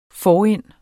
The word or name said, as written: forind